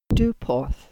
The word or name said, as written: Duporth